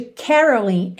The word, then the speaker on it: caroling: A sílaba tônica de caroling é a primeira, mas aqui a gente tem um alongamento dessa última sílaba, então a gente tem caroling.